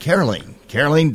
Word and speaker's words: Caroline: The way you pronounce her name is just like Jolene, Caroline Dodson, running for Secretary of State.